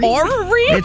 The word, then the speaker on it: orrery: So it's O-R-R-E-R-Y. Orrery? – Orrery? – Orrery? – It's Orrery.